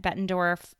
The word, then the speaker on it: Bettendorf: Bettendorf High School, am I pronouncing that right? – Yes, you are.